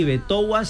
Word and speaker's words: Betowa: ¿Cómo se escribe Betoua? – B-E-T-O-W-A. – Ah, con W. – Y escribe así, Betoua Cena.